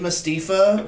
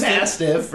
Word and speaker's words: Mastiff: Dave Mastifa versus Eddie Dennis. – What? – I said it wrong on purpose. Dave Mastiff versus Eddie Dennis in a no DQ match.